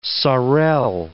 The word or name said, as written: saurel